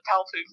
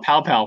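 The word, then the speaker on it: paopu: You got the name right, the pow poo fruit. – Yeah! I always want to call it pow pow fruit.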